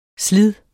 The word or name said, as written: slids